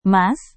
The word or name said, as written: mas